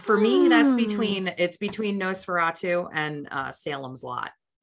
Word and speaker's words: NOS4A2: Yeah, like in Nosferatu, which if you're not familiar with that book, it's a license plate, so that's how you pronounce it, but it's NOS4A2. Nosferatu.